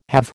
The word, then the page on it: halve: American English